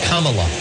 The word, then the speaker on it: Kamala: Foreign and mispronounced her name as vice president. Let's hear that again. It's Kamala.